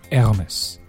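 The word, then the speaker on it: Hermès: 熊叔在这里教大家用一种非常高大上的读法，Hermes， Hermes，来自于法国的爱马仕啊。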